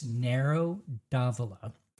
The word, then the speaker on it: Nero d'Avola: So anyway, it's Nero d'Avola.